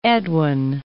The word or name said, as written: Eadwine